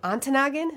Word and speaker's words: Ontonagon: I'm Ontonagon.